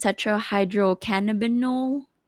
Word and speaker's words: tetrahydrocannabinol: I believe that's pronounced tetrahydrocannabinol Cana, cannabinol.